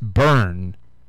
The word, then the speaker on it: Byrne: I guess it's pronounced burn.